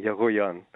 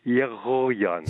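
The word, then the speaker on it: Egoyan: Yagoyan. But it's very difficult to pronounce for people. Honestly, it's uh, for the English uh, tongue, it's very difficult because it's Yagoyan.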